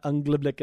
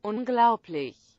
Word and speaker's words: unglaublich: I think that as close as I got was Unglaublich. But it is not Unglaublich at all. It is Unglaublich.